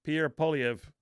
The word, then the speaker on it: Pierre Poilievre: This is Canadian conservative leader Pierre Poilievre. Am I saying that right? I why can't I get that right? – Oh, no, you got it right there.